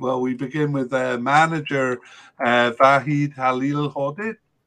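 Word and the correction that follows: Vahid Halilhodžić: I'm not sure if I'm saying that right. – Yeah, Vahid Halahodic.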